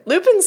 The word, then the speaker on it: Lupin: Lupin sounds like, like loopy. Like, I'm Lupin. – Lupin. – I'm Lupin. Yeah. Today. I'm just Lupin. – I'm Lupin, man. So I will always pronounce it as Lupin.